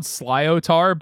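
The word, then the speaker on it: sliotar: So it's spelled Slyotar, but I looked up the pronunciation because I didn't want to make people mad at me, but it's Slyotar.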